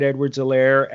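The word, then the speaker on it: Edwards-Helaire: So we understand that the proper pronunciation is the H is silent. So we're adopting Clyde Edwards Elaire as an honorary Mexican.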